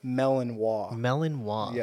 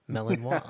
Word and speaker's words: Malinois: Am I pronouncing that correctly? – Malinois. Yeah, so Belgian Malinois. – I'm going to think melons and me. Malinois. – There you go.